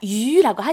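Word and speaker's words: 유 (鱼): 뭐 생선의 발음 혹시 아시나요? – 위? – 그렇죠. 예, 맞습니다. 유라고 하죠. – 위? – 예, 네. – 유. 네. 유라고 하는데요.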